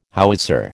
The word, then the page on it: howitzer: American English